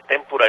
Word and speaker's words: témpura: Bernardo Benítez Vadillo, nos dice, témpura es palabra grave, acento prosódico en la penúltima sílaba.